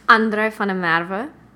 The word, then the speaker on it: van der Merwe: André van der Merwe.